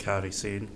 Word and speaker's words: Kairi Sane: And we've obviously, is it Kerry Sen? Is that, is that, did I pronounce that right? Whoever won that? Kairi Sane. Gary Sane, thank you.